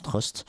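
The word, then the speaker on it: R/T: ça s'écrit r slash t mais on prononce Trust.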